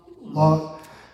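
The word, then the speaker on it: Lotz: How do you say her last name? Lutz.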